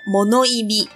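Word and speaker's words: 物忌: 它是物品的物，然后忌讳的忌，日文念作 monoimi。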